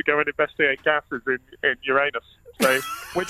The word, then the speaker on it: Uranus: Well, Patrick Moore always used to say Uranus in a desperate effort to avoid the titters. But it is, I'm afraid, Uranus.